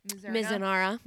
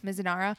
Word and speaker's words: Mizunara: The Mizunara. – Mizunara.